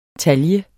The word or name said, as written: TALJER